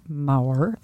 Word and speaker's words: Maurer: Okay, people can find me on my website, Candida Maurer. It's Maurer. I never say it right, so ignore how I say it, but don't ignore how I spell it, .com.